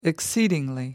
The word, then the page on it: exceedingly: American English